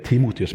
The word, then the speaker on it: Timotius: Eh, Paulus eh, har delt evangeliet med Timotius. Det er vel tror jeg, Timotius som er riktig uttale.